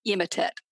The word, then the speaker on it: Emotet: I've just always settled on the Russian pronunciation, so I call it Emotet.